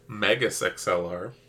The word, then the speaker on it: Megas XLR: Mine is Mega XLR. – Megas XLR. – Oh gotta pro- you gotta pronounce the S.